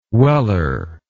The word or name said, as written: Weller